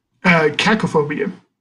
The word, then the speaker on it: Cacophobia: And then, is it how do you pronounce the last one? – Cacophobia.